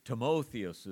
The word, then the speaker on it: Timotheus: Timotheus is the way you would pronounce the old Greek name.